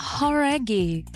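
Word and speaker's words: Jauregui: É Lauren Horaghi. Talvez você já tenha ouvido falar dela, ex-integrante do grupo Fifth Harmony. – Horaghi. Who é? – Horaghi. – Horaghi. – Então a gente tava falando errado, cara.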